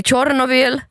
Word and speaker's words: Tjernobyl: Men man ska börja tala om liksom kärnkraftsolyckan i Tjernobyl.